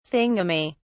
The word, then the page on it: thingummy: British English